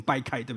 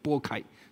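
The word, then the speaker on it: 擘开: 你再想路加福音第二十二章第十九节所描述的，主被卖的那夜，他拿起饼来祝谢来拨开，我们刚刚念成掰开对不对，应该都拨开，所以有的时候我们把主餐聚会称之为叫什么拨饼聚会，不是掰饼聚会对不对，拨饼掰开拨开递给门徒说，这是我的身体为你们舍吧，你们当如此行为的是纪念我。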